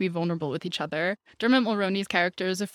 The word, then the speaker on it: Dermot: Yeah, Dermot.